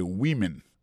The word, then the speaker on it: women: Mais tandis que les mots femme nan, au pluriel, qu'on a pas prononcé woman, encore c'est women. Many women.